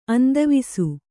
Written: ಅಂತವಾಸ

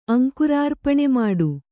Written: ಅಂಕುರಾರ್ಪಣಮಾಡು

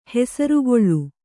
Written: ಹೆಸರ್ಗೊಳ್

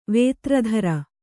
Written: ವೇತ್ರಧರ

